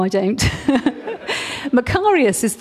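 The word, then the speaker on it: Makarios (μακάριος): Am I saying it right? She's a Greek. She speaks fluent Greek. Oh, there she is. And I said, is it Makarios? Yes, Makarios.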